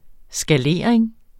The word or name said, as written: skalering